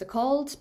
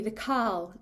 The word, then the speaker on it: Cauld: So and I guess in Lowland Scotland it would be pronounced The Cauld, but in the northeast it would be The Cal.